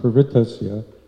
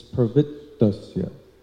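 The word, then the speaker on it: pravṛttasya: Somehow it's almost like it's kind of a bit of a subtle thing, but it's not pravrittasya, it's pravrittasya. Here, both T's, pravrittasya. Otherwise, you don't get enough syllables if you don't do that little bit of a pause there. Pravrittasya.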